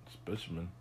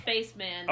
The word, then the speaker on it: Spaceman: He's a doctor, and his last name is very clearly Spaceman. But they pronounce it Spaceman.